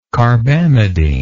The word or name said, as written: carbamidine